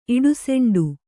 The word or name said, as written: ಇಡುಸೆಂಡು